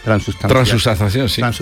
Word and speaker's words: transustanciación: Pues fíjate tú que Corpus Christi no es solamente Corpus, también es Corpus el otro, es el milagro de la transustanciación, ¿no se dice? – Transustanciación, es el cuerpo y es también la sangre.